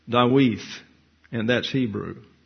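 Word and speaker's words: Dawith (דוד): So the second D in David does not contain the dagish lini, so it's pronounced like the diphthong TH as in the word thee. So it's Dawith.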